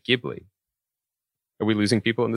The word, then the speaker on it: Ghibli: It's pronounced Ghibli, a hard G. This is the GIF thing all over again. But when it is translated into Japanese and spelled out in katakana lettering, it's a little bit more like Ghibli.